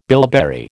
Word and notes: bilberry: American English

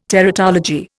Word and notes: teratology: Indian

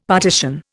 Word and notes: partition: Indian

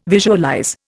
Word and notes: visualize: Indian

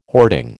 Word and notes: hoarding: American English

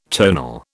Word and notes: tonal: British English

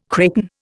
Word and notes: kraken: Indian

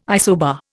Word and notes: isobar: Indian

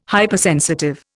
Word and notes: Hypersensitive: Indian

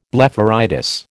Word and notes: blepharitis: American English

